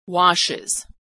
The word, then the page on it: washes: Iz